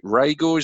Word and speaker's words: Reagor: is it Reagor, is that how you pronounce it, Lee? – Yeah, that's right.